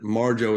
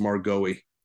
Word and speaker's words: Marjoe: I mean, but it's hard in a college lecture hall to try to explain to somebody that Marjoe is not pronounced Margoe.